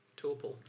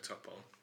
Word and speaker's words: Topol: Is it Topol or Topol? – I would say Topol. – The thing is, I heard it pronounced three different ways. – Topol is the guy from Fiddler on the Roof, and it's spelt the same way. – Right. – So I'm going with Topol.